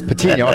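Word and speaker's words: Patiño: Alejandro Patino. – Actually, if you want to say it right, it's Patino with an N. – It's Patino.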